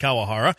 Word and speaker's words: Kawahara: Yeah, it's Kawahara. It's K-A-W-A-H-A-R-A. Kawahara. I think I pronounced it right. – That sounds right.